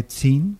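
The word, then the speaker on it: tzin: Et le Tsin ne s'écrit pas parce qu'on n'a pas besoin. C'est juste par le rapport graphique entre le nom et le personnage important où on prononce la syllabe de respect.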